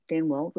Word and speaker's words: Duenwald: That's Sarah Denwald, which is D-U-E-N-W-A-L-D, the U is silent, and Nancy McSherry Jensen.